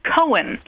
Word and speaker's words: Cohen: Cohen. – Oh, yeah, Cohen. – So, a good boy's name, yes. – Mm-hmm. It rhymes with Owen.